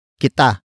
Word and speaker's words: kita: The next word is kita. Kita. Kita. Kita is spelled as K-I-T-A.